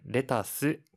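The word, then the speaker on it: レタス: はい 양 상 추와 양 배추 는 레 타 스 캬 베 츠 와 같 이 영 어 를 일 본어 식 발음 으로 표 현하니 주 의해주 세 요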